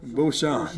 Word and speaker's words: Beauchamp: Beauchamp.